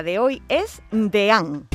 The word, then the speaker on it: deán: Creo que ha habido una periodista en algún sitio, o periodista, que ha dicho que lo ha pronunciado como DIN.